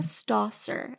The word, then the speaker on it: Stawser: It's A Million Reasons Why, and it's by Jessica Stosser, S-T-A-W-S-E-R. She has... – Wait, say her name again. – Jessica Stosser, S-T-A-W-S-E-R.